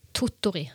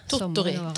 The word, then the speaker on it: Tottori: Var det rett uttalt? – Totori, Tottori.